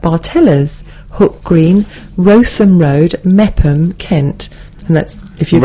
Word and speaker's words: Meopham: I mean, Mepham, M-E-O-P-H-A-M.